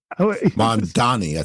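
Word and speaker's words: Mamdani: You mentioned the Mondavi. Is it Mondavi? – Mondani, I think it is. – Mondani, thank you.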